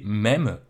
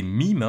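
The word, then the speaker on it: meme: aujourd'hui on va parler des mèmes ou des mimes. Hein. Je fais un petit point de prononciation pour commencer. Vous êtes au courant que c'est un terme anglais, donc on devrait le prononcer mime et non mème,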